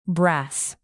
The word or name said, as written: brass